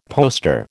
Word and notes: poster: American English

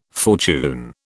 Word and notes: fortune: British English

